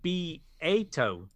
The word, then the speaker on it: Beato: Rick Beato, pronounced Beato. There you go. – Is this going to be a Rick Beato.